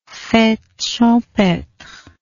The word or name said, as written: fete champetre